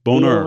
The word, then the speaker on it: Bonheur: Bonheur. – B-O-N-H-E-U-R.